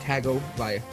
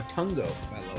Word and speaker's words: Tagovailoa: It is the first start for Tua Tagovailoa, or Tungo Vailoa, I think it's pronounced. Tungo Vailoa. No, I said that wrong.